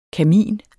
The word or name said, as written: kamin